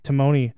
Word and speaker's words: Timoney: And that Nick, how do you say his last name? – Timoney.